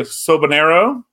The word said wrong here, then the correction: Soberano: Jr.? – Soberano. – Soberano. Oh, yeah. Sober, like as in sober as in I don't drink.